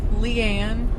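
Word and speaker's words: Lee-Ann: Leanne. – That's nice. Aww. – It's spelled how? L-E-E. Shut up, Jonathan. Sorry. L-E-E hyphen A-N-N.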